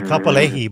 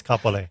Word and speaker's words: Kapolei: I was going to say Kapolei, but it's not Kapolei. It's Kapolei. Keep going.